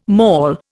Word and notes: mol: Indian

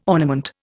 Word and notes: ornament: Indian

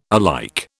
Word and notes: alike: British English

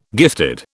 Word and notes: gifted: British English

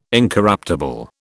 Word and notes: incorruptible: British English

